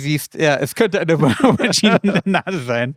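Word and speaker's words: René Auberjonois: Unter anderem eben auch der Erwähnung, dass ihr Mann Yu, der hier gespielt wird von René Aubergenois. – Aubergine. – Ja. – Wird er ausgesprochen.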